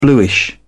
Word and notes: bluish: British English

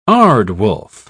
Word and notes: aardwolf: British English

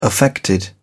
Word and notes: affected: English; British English

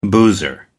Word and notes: boozer: American English

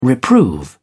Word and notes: reprove: British English

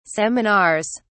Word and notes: seminars: British English

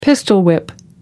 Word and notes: pistol-whip: American English

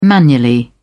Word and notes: manually: British English

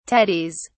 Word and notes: tatties: British English

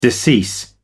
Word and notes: decease: American English

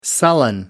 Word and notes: sullen: American English

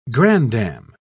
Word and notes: grandam: British English

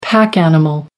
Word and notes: pack animal: American English

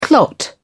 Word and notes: clot: British English

